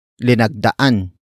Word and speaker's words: linagdaan: Word for word it's linagdaan niya ang petisyon.